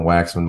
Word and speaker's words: Waxman: And you can imagine Waxman, my last name. I got teased growing up. Waxy. So that's it's spelled as it sounds.